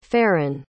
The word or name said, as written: farren